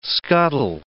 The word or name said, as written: scuttle